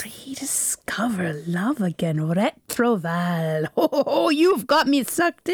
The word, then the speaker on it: Retrouvaille: We're a community, and the correct, well, no, I'll give you my pronunciation with my cough, and also not with my best French accent, but it should be Retrouvaille. But we say Retrouvaille.